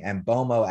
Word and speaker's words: Mbeumo: I've been told that's actually how you pronounce his name, Mbeumo, as in FOMO.